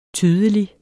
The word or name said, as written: tydelig